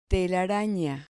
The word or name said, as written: telarana